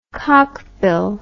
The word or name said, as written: cockbill